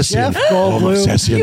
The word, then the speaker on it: Goldblum: Jeff Goldblum.